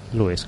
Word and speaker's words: Lewis: ¿Lewis o Lewis? Lewis Carroll, yo creo que es. Se pronuncia Lewis, eh.